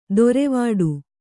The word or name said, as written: ದೊರೆವಡು